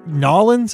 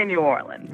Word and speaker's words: New Orleans: Hey, how do you say Nolens? Is that how you say it? I'm trying to get it right here. – We say New Orleans.